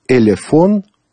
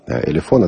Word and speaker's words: Elephone: А в этом описании устройства написано, что это, внимание, Elefon P2000. Elefon пишется E-L-E-P-H-O-N-E, дальше буква P-2000.